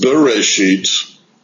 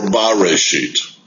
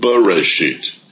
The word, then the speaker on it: Bereshit: So it can be pronounced Bereshit or Bareshit. Bareshit means in the beginning. But the Masoretic text teaches us to pronounce it Bereshit, which means in a beginning.